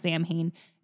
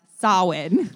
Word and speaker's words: Samhain: It's spelled S-A-M-H-I-A-I-N. It's not pronounced Samhain. It's pronounced Samhain.